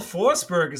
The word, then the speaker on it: Forsberg: His last name is spelled Forsberg. How do the Swedes pronounce Berg? B-E-R-G. – Berg. – Yeah, Berg or Berg, right?